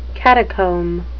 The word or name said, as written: catacomb